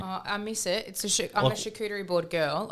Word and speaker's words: charcuterie: I do like a nice charcuterie board. You know what one of those is, obviously, Dane. So yeah. Is that how you say it? – Charcuterie. – Yeah. – Yes. Charcuterie.